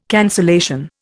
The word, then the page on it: cancellation: Indian